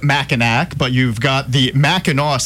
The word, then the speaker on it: Mackinac: And by the way, it is pronounced Mackinac. We do things weird up in Michigan. It's spelled Mackinac, but pronounced Mackinac.